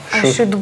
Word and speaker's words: should: То есть I should go, это мне надо бы идти пойти, да? – А вот это L не читается, получается.